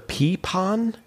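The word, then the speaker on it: pipón: Just because you have so much knowledge and you're spewing it quickly, say the word a peepon? – Peepon. It's spelled P-I-P-O-N. – Okay. – But it's a peepon.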